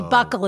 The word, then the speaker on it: buccal: I didn't realize the buckle was B-U-C-C-A-L. Which I didn't either, actually. – Yes, which I always thought was buccal, how you said that. But it must be buckle is how you pronounce it.